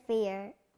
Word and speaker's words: fear: Ear, ear, fear. Ear, ear, fear. Ear, ear, fear. – 너무 잘했어요. 또 어떤 단어가 ear 소리 났죠?